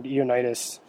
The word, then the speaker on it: Ioannidis: There's a doctor named Ioannidis, actually is his pronunciation.